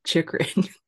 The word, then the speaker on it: Chychrun: Chychrun.